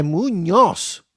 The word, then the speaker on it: Muñoz: Munoz, creo que era Munoz. Ah, es Muñoz. Muñoz. Muñoz. no, pero espérate, si lo dice un gringo, acuérdate que los gringos no pronuncian la ñ.